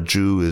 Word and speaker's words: Chu: Little Soldiers, An American Boy, A Chinese School, and the Global Race to Achieve by Lenora Chu, or Lenora Zhu as it would be pronounced.